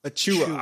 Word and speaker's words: Achiuwa: Let me try to say this correctly. Precious Achiuwa. – Achiuwa. – Ah, I was so close. Okay. Like you're anyway, chewing go ahead on and, food. Okay, like chewing on food.